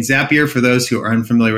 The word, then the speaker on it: Zapier: How about Zapier? That is like, or maybe it's Zapier. I think it's supposed to be Zapier rhymes with happier.